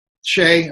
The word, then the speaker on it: Tsai: Well, we learned in San Antonio that the out council member, Tony Brooks Tsai, T-S-A-I, and his husband, Philip Ty Brooks. – It might be Shay. – Shay, I'm sorry, I mispronounced. – I'm guessing T-S-A-I.